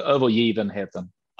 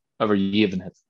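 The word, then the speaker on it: Övergivenheten: Is it Overgiven Hetten? – That's pretty good. We actually, the G is pronounced like a J. So Overgiven Hetten. – Okay. – Overgiven Hetten.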